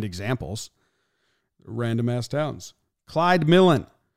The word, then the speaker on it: Milan: It looks like it's pronounced Milan, M-I-L-A-N, but it's pronounced Millen.